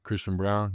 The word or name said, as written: Christian Braun